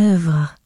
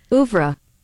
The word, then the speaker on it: oeuvre: How about this one? – Ouvre. – Okay, that's Ouvre. Bad.